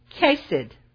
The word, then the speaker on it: Chesed: It's spelled C-H-E-S-E-D, and it's pronounced Chesed. Chesed.